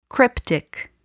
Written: cryptic

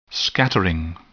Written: scattering

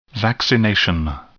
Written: vaccination

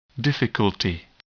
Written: difficulty

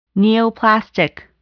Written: neoplastic

